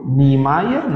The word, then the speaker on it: Niemeyer: A família de hoje, a família Niemeyer, se escreve N-I-E-M-E-Y-E-R. O pessoal no Brasil fala Niemeyer.